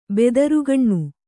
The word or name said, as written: ಬೆದರುಗಣ್ಣು